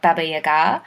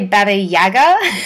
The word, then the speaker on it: Baba Yaga: So you get people like Baba Yaga, Oh, wow, you actually pronounce it correctly. – Yes, people tend to say Baba Yaga, yeah, especially here in Australia.